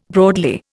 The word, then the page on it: broadly: Indian